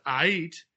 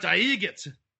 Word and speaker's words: Aight: I'm Count Aiget. No, you're Count Ait. Aiget. He's pronouncing A-I-G-H-T as it really, you know, looks. Aiget. No, it's Ait. Like, everything's all right. Like, everything's Ait.